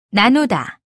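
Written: 날씨

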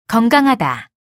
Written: 건강하다

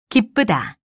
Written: 기쁘다

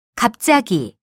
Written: 갑자기

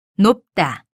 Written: 높다

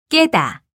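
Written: 꼭

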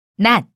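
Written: 내리다

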